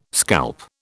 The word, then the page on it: scalp: British English